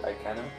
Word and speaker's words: Ikana: Ikana? – Ikana. – Ikana, so it's a long A.